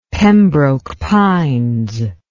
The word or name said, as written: Pembroke Pines